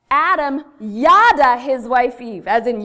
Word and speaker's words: yada: And write this down, because the word yada, which is the correct Hebrew pronunciation, means to know, to be deeply known and deeply respected.